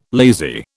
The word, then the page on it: lazy: British English